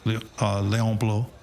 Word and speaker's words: Bloy: There was a French novelist, and I didn't know who the guy was at the time, but in English it's Léon Bloy, B-L-O-Y, but like in French, my French pronunciation always terrible, Léon Bloy, right.